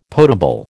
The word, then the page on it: potable: American English